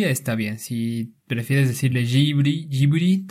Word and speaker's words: Ghibli: Y haya notado que la pronunciación es Jiburi. Jiburi, además, con esa bu como pequeñita ahí inserta, ¿no?